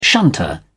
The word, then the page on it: shunter: British English